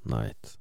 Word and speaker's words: Knight: Uttalas exakt som natt, Knight.